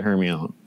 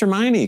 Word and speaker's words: Hermione: And then, like, you also have a first grade teacher who then reads you the book and she also pronounces it Hermione. And then you're like, this is clearly how you pronounce this word. And then several years later, you have to get that out of your head on like the fourth or fifth book because, oh, her name's Hermione.